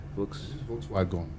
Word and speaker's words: Volkswagen: Usually, they are like, I think, Volkswagen. – Yeah. – Volkswagen. I don't know how you pronounce it. – It's actually Volkswagen.